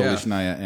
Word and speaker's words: Stolichnaya: They actually recommend Stolichnaya. I don't know. You Stoli. – Know. Stoli. Yeah. – Stolichnaya.